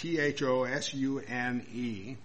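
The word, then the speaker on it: agathosune (ἀγαθωσύνη): Now you can go ahead and spell that, but it's spelled agathosune with an accent on the E at the end. Agathosune.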